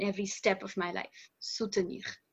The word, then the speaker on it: soutenir: So when you're trying to say that, you would use soutenir. Not supporter. – Mm-hmm. Yeah. Difficult one to pronounce there. Soutenir. – Soutenir. – Yeah. Soutenir. Sounds very French.